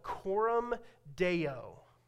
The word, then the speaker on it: coram deo: So there's this Latin term called coram deo. Coram deo. It's spelled C-O-R-A-M-D-E-O.